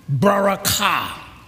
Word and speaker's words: barakah: Now, the blessing is a word, barakah. Barakah. The accent is on the ka.